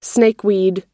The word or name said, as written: snakeweed